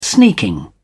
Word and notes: sneaking: British English

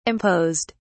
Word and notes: imposed: British English

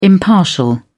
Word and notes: impartial: British English

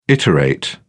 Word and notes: iterate: British English

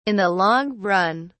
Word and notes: in the long run: British English

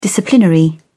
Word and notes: disciplinary: British English

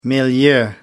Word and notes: milieu: American English

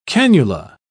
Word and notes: cannula: British English

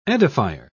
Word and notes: edifier: British English

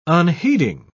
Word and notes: unheeding: British English

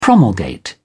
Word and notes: promulgate: British English

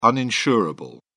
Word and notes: uninsurable: British English

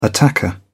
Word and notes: attacker: British English

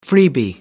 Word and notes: freebie: American English

